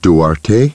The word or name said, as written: Duarte